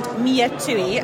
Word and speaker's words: Miatui: So I first got involved with this bag company called Miatui, and it's spelled M-I-A-T-U-I.